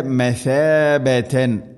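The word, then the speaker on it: مثابة: ജഅൽന ജഅൽന ബൈത മസാബതൻ. മസാബത്തല്ല സീനല്ല ഥ. ഉച്ചരിക്കുന്ന സമയത്ത് നാവിൻ്റെ അറ്റം മുൻപല്ലുകളുടെ ഇരുനിരകൾക്കിടയിലൂടെ അല്പം പുറത്തോട്ട് നിൽക്കണം നേരിയ തോതിൽ. മസാബത്ത് മസാബതൻ ലിന്നാസ്.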